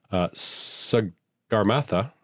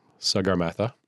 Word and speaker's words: Sagarmatha: In Nepali, it's called Sagarmatha. Sagarmatha.